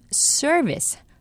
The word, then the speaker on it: service: 이 서비스의 정확한 영어 발음은 R 발음이 들어가는 service, service이고요.